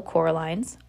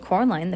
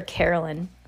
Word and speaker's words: Coraline: Once upon a time, Miss Spink and Miss Forcible had been actresses, as Miss Spink told Coraline the first time she met her. "You see, Coraline," Mrs. Spink said, getting Coraline's name wrong. Oh, she calls her Caroline. Caroline.